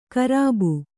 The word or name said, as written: ಗರ್ಭ